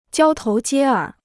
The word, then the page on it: 交头接耳: jiāo tóu jiē ěr